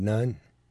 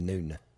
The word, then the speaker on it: Nun: She actually says, son of Nun. On there and everywhere else is pronounced actually son of Noon.